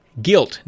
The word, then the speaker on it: Gylt: He says, Guilt. Now that's spelled G-Y-L-T out there, folks. Guilt on Google Stadia.